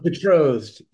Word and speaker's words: Betrothed: And also from Aftershock, you wrote Clan Killers and Berothold? – If I pronounce that. Oh, Betrothed.